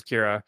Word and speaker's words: Kira: Is it pronounced Kira or Kyra? So I don't know. I think it's Kyra. Kira. But it's K-I-R-A. Is that whatever? – I think it's Kira.